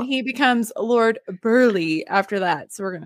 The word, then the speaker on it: Burghley: Well, it's spelled B-U-R-G-H-L-E-Y, but I think it's still Burley.